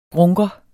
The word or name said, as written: grunker